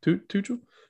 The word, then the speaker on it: Tuchel: But yeah, I mean, it was evident when Tuchel, am I saying that right? – Yeah. – Tuchel.